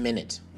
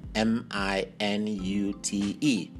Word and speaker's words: minute: So one minute, you spell it as M I N U T E. That's minute. But this same word sometimes is pronounced minute. Minute the spelling is the same, but it's pronounced minute, which is referring to something very tiny, something very small.